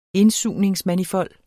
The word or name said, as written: indsugningsmanifold